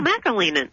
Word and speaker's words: McAleenan: Oh, McAleenan. – McAleenan. – McAleenan, which makes me think of the iconic. I can just see Kevin McAleenan now doing the Macarena. Kevin McAleenan.